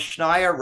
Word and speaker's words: Schneier: I always tell them Schneier rhymes with frequent flyer.